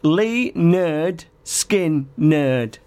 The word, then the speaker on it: Lynyrd Skynyrd: And it's Lynyrd Skynyrd is how you're supposed to pronounce it.